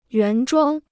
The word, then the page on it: 原装: yuán zhuāng